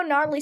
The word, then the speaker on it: gnarly: Never see those silent G's coming. – But then it's like my mom's reading over it, and she's like, you know gnarly spelled with a G?